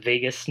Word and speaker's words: vagus: I'm going to do some diaphragm exercises, and it's going to, the other thing it affects is your vagus nerve.